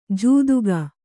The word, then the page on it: ಜೂದುಗ: jūduga